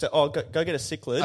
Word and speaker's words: cichlid: It's pronounced cichlid. – Not kitten.